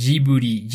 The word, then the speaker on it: Ghibli: Y haya notado que la pronunciación es Jiburi. Jiburi, además, con esa bu como pequeñita ahí inserta, ¿no?